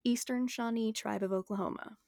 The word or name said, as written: Eastern Shawnee